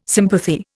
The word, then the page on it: sympathy: Indian